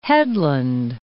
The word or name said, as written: Port Hedland